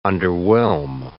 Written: underwhelm

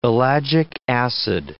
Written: ellagic acid

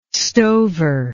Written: stover

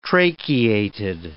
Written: tracheated

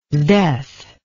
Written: sdeath

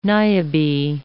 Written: Niobe

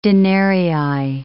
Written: denarii

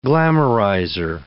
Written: glamorizer

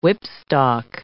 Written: whipstock

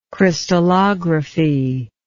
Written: crystallographer

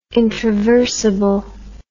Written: introversible

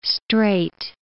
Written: strait

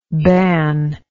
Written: bannable

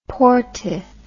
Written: poortith

